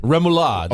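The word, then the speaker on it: rémoulade: How do you say the sauce? Remoulade? – Remoulade, yes.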